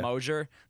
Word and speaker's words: Mosier: Is spelled, yeah, Mosier. They just pronounce it the Canadian French way.